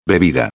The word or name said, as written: bebida